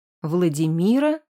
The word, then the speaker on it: Владимира: Владимира, с ударением на вторую «и», была моей хорошей знакомой.